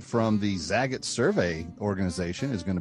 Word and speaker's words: Zagat: Zagat rhymes with the cat in the hat.